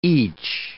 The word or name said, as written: each